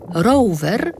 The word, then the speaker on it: Rover: Takie wszystkie pojazdy nazywane były velocypedami i właśnie velocypedy produkowała około 150 lat temu firma angielska, której nazwę pisze się tak jak dzisiaj, czyli R-O-V-E-R, a czyta się po angielsku Rover.